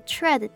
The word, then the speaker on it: tread: 那这里的 tread T R E A D，它的 E A 我们是要发 a 的声音。